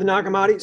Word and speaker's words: Nag Hammadi: So yeah, the Nag Hammadi.